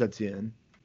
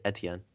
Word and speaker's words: Etienne: Jets go Travis Etienne. I think I pronounced his name wrong. But no, I think you had it, Etienne.